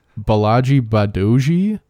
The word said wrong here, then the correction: Bolaji Badejo: It's Bolaji Badejo.